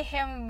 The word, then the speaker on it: him: You only see him when you're very young. Him は早く、ひしか聞こえないような感じで発音してください。